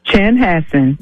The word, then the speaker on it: Chanhassen: Chanhassen? – Chanhassen. – Chanhassen.